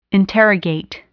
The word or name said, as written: interrogate